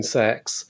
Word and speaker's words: CeX: When I saw Horns for £1 in sex, which is the correct pronunciation of CEX.